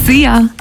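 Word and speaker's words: Szia: Sia. Hallo und tschüss. Könnt ihr euch ganz easy merken. Wird einfach ausgesprochen wie unser Energy Star. Sia.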